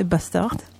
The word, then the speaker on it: BSTRD: Euh, c'est les euh, t majuscules BSTRD. C'est bastard.